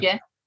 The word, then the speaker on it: Hygge: Hygge.